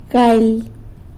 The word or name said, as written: galy